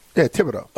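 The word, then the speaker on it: Thibodeaux: So Tib, not Thib. – Yeah, Thibodeau. – Okay. – The H is silent.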